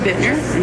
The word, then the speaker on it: Bittner: It's Barbara Bittner, and it's spelled B-A-R-B-A-R-A, and my last name is B-I-T-T-N-E-R.